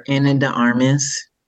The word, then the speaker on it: Ana de Armas: It's like Ana de Armas. I don't know how to say it. – Ana de Armas.